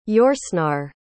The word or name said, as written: Yourcenar